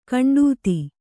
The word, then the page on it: ಖಂಡಿತೆ: khaṇḍite